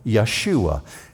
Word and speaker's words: Yeshua: But the Hebrews would have pronounced it Yahashua, or over time it became known as Yahshua. Jesus, in the New Testament, is called, among the Hebrew people, Yeshua.